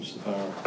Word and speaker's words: Sapphira: How you pronounce her name? Sapphira. – That was pretty quick.